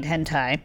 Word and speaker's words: hentai: Hentai which in the pronunciation does also have the long vowel over I, so it is indeed hentai, is a genre of Japanese manga and anime characterized by overtly sexualized characters and sexually explicit plots and images.